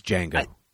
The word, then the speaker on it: Django: But I will say, getting back to Django Unchained, I think it's Django. The D is silent.